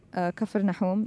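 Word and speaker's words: Kafir Nahum (كفرناحوم): Kafir Nahum, that's how it's pronounced in Arabic, written Caper Nahum.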